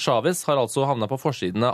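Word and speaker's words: Chávez: Som mange sikkert har hørt nå, så døde altså presidenten i Venezuela, Hugo Chavez, eller Chavez, litt usikker. Vi kan jo spørre eksperten som er med oss med en gang, Joar Hoel Larsen. Hvordan uttaler man et navn på den mannen? – Chavez.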